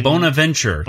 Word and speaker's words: Bonaventure: The Bonaventure. That's how you say it.